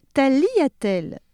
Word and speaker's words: tagliatelle: Pour finir en beauté, tagliatelle, parce que c'est un mot italien qui signifie petite tranche, qui vient de tagliare, le G ne se prononce pas en italien devant le L et ce tagliare a la même origine que le verbe français tailler.